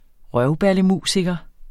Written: røvballemusiker

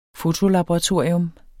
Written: fotolaboratorium

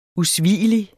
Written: usvigelig

